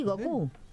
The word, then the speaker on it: Goku: ¿No es Goku? ¿No se dice así? – Sí, Goku.